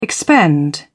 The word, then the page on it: expend: British English